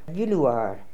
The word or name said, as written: Vilooar